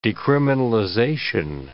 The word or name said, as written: decriminalization